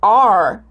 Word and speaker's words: are: Você não vai pronunciar a letra E, ok? Você vai terminar no R. Vai subir a língua para cima, vai dobrar e vai colocar potência aí na sua voz.